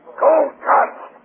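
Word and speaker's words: Cole Kutz: His name is Cuts, K-U-T-Z. – What's his name? – His last name is K-U-T-Z, Cuts. It's pronounced Cuts. – Anybody's name is Cuts? K-U-T-Z, Cuts.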